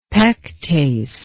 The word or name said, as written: pectase